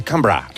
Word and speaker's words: Canberra: My lords, my ladies and gentlemen, welcome to Canberra. – Que no se dice Canberra, se dice Canberra.